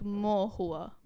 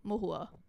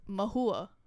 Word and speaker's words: Mohua: the Bengali s- pronunciation is M, like Mohua, Mohua, Mohua, like quick. Whereas the English, American pronunciation is Mahua, Mahua.